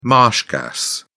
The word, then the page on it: marsh gas: British English